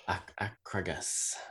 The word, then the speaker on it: Akragas: How would you pronounce that again? It's, where is this gone? Because I had a diamond. – Akragas. – Akragas. – Yes, I'm sure I pronounced it 9 or 7 different ways before now, but yeah, Akragas. – Well, if you look at Wikipedia, there's about 15 different ways of pronouncing it. – Yeah, so you know, apparently I say in Italian, it's Agrigento.